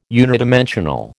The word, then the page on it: unidimensional: American English